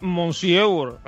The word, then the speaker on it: Monsieur: como, ¿Messier? me, es Monsieur.